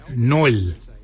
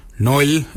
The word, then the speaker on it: nyooly: Nail is spelled N-G-A-R-L-Y. Nail for underarm. For your elbow, we say noil. Noil is spelled N-Y-O-O-L-Y.